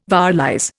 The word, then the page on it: vowelize: Indian